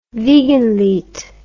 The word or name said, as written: Wiegenlied